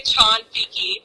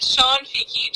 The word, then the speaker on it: Cianfichi: So, the Italian way to pronounce my name, C-I in Italian, is a ch sound, like C-H. It's actually Shanfiki. My whole life, my mom has always pronounced it Shanfiki just because it seems easier for people to say.